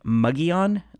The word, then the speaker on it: Mogollon: However, I'm from Arizona where we pronounce it Mogollon, like the Mogollon Rim,